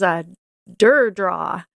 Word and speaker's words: Durdraw: So this is Durdraw. Durdraw is actually a very cool ANSI, ASCII, and Unicode art editor for Unix-like OSs, like Linux and Mac OS. And this is so old school and cool. Durdraw also does animation, like a traditional animation studio, where you can edit the animation a frame at a time, which is awesome.